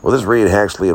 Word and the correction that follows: Hadley: and Walter Knuxley and Reed Hadley. Excuse me, I mispronounced my own last name.